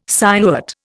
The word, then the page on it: sinuate: Indian